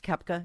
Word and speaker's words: Koepka: It features Serena Williams, Peyton Manning, Anthony Davis, golfer Brooks Koepka. Is that how you say his name? – Mm-hmm.